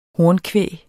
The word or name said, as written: hornkvæg